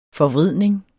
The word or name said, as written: forvridning